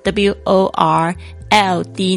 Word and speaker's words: world: 特别注意一下这个 world，就是世界的意思，然后注意一下它的发音是 W O R L D，那个 L 的声音要出来，world。